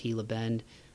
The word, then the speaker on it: Gila: Gila Bend, it's spelled G-I-L-A, but I think it's pronounced Gila Bend.